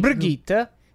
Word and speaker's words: Brigitte: In particular, they added a character called Bridget, a support tank. – Brigitte. – Brigitte, yes, thank you. – Is that how you pronounce it? Brigitte? – Yeah, it is.